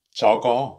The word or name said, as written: that